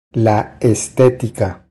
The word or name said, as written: La estetica